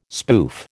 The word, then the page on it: spoof: American English